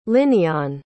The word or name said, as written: linneon